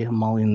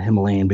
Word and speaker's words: Himalayan: So this is why Tibet and and the the Himalayan mountains, and I say Himalayan instead Himalayan because that's actually how it should be pronounced.